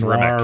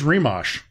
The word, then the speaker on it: Rimac: He said it was Rimac. – Oh, okay. Okay. – Well, he said Rimac, but I found a German guy on YouTube today who said it's Rimac.